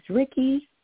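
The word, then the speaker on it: Riqui: And my name is Ricky. – You say R-N-G. – Could you spell that Ricky for us? – It's R-I-Q-U-I.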